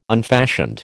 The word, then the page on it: unfashioned: American English